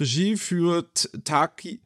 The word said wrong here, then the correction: Takaaki: Tak wie spricht man das aus? Stopp, wo ist er? Wo ist er? Takaaki. Taka, ah, Takaaki.